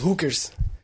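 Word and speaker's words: Hulkers: Inclusive, eu, eu tô chamando agora que a minha equipe são os Hookers. Né? Só que hookers é H-U-L-K-E-R-S, né? De Hulk, mas a pronúncia é igual ou quase igual a de hookers de prostituta, né?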